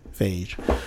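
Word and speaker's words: Feige: so Kevin Page or Phage, I can't even pronounce this guy's name. It's like beige, but with an F. So Phage. Apparently, he clears up all the rumors about what this merger means for all the future Marvel films.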